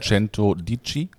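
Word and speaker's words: Centodieci: Verbessere mich, wenn ich es falsch ausspreche. – Ja, Cento Dici, kann das sein? – Ja. Bugatti Cento Dici.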